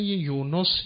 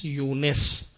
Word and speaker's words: یونس: و ان یونس لمن المرسلین، کلمه یونس، یونس هر دو درست است، مانند یوسف یوسف.